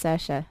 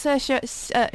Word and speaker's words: Saoirse: Only last week I realised it was actually pronounced Saoirse. – Oh, it took ages because it's Saoirse.